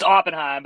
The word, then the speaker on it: Oppenheim: Is that how you say it? – It's Oppenheim, but I really...